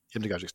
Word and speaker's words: hypnagogic: Hypnagogic state, is that correct? Is that how you pronounce that? – Hypnagogic state.